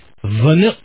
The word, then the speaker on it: Wnuk: And the W is pronounced like a V. Please welcome Nick Vanuk.